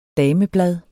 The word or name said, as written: DAMEBLADET